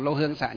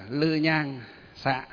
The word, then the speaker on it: lư nhang xạ: Thì không phải là lô hương xạ, không đọc là lô hương xạ nhiệt mà đọc là lư nhang xạ đó.